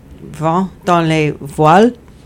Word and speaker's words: Vent dans les voiles: Vent dans les voiles. Did I say it right, Steph? – You got it. Perfect.